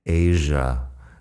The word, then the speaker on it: Asia: Fíjate primero en la pronunciación de Asia con j en medio. Es un sonido no muy común en inglés, pero sí que se usa a veces. Asia.